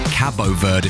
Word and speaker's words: Cabo Verde: actually a teacher's texting and says, I've got a pupil in my form from Cape Verde, and she goes bananas if you mispronounce it. So Cabo Verde, if you want to be official about it.